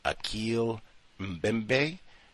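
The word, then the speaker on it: Achille Mbembe: Okay, so it is Akil, and we, pronunciation, you cross our fingers, apologies, victim, Akil Mbembe, so it's A-C-H-I-L-L-E, last name M-B, as in bottom, E-M-B, as in black, E, Akil Mbembe.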